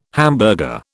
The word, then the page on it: hamburger: British English